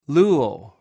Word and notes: luw: greek

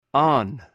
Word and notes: an: Greek

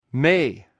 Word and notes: mh: greek

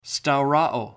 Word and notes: stauroo: Greek

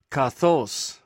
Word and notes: kaqws: greek

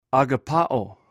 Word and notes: agapaw: greek

